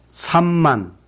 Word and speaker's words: 삼만: Be sure to pronounce M twice in 삼만.